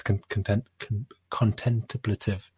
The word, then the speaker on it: contemplative: It's more just a nice contemplative. How do I say that? Complentative? Content? How do I? – Contemplative? – Contemplative. Contemplative.